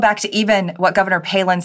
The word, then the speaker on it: Palin: from all the news outlets were calling her Pollen. They didn't know she was even on the shortlist. They didn't know how to pronounce her last name.